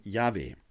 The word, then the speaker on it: Yahweh: So, und man schreibt im Hebräischen nur die Konsonanten JHWH, ausgesprochen Yahweh.